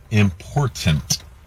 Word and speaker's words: important: The problem is you're mispronouncing it. It is important. like my girlfriend and wife say, mind the T's.